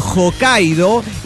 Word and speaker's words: Hokkaido: La H suena como J, Yokohama, ese Hama se escribe con H pero es con J, se dice con J, Hokkaido, y la J suena como Y, es decir, Gaijin, Romaji o Meiji.